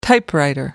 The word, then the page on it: typewriter: American English